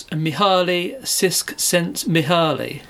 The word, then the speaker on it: Mihaly Csikszentmihalyi: That's Mihaly Csikszentmihalyi.